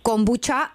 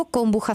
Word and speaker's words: kombucha: Máte taky na Slovensku kombuchu a vyslovuješ to kombuča anebo kombucha s ch. – No. – Máme kombuču. – Kombuču, mladý už jenom č. – Mladý říká jenom č.